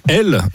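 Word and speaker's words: Hel: Elle, une petite ville balnéaire au bout d'une presqu'île sur la Baltique, dont le nom se prononce exactement comme Hell en anglais, soit l'enfer.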